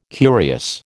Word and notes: curious: American English

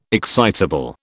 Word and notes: excitable: British English

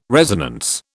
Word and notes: resonance: British English

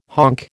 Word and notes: honk: American English

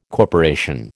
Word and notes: corporation: American English

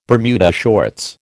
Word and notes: Bermuda shorts: American English

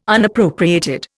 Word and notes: Unappropriated: Indian

